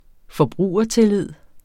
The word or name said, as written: forbrugertillid